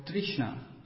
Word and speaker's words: Trishna: So there's this word Krishna, and there's one very nice word which rhymes with Krishna. It's called Trishna. Instead of K, you just put T.